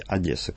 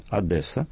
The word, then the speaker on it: Одесса: Это название города Одесса. В соответствии с литературной нормой нужно говорить «Одесса», как я и произнес. Но, тем не менее, одесситы предпочитают вариант с твердым «Д» – «Одесса».